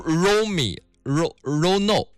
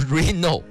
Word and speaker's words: Reno: 怎么这么多爱莫能助的朋友？Romi Rono 啊，Reno Reno 啊，好多人说，他说这个应该读 Reno 啊，R E N O 啊，Reno 啊。